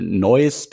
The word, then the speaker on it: Noyes: Also ich, ich vermute mal, es spricht sich äh, Neues Technologies aus, also äh, N-O-Y-E-E-S geschrieben.